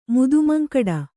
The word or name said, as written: ಮುದುಮಂಕಡ